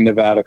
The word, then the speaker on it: Nevada: Well, a statement that I made at a presentation, live presentation I did over the weekend here in Las Vegas, and thanks for pronouncing Nevada correctly.